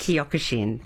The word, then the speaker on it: Kyokushin: Kyokushin.